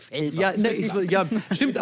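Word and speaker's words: Fellbach: Es gibt aber so viele Leute sprechen das falsch aus, weil wir auf der anderen Rheinseite sind, die denken, das ist schon so weit an Frankreich, dass man das. – Fellbert? – Ja, ja. So viele Leute wollen das immer so aussprechen, deswegen du hast das genau richtig ausgesprochen.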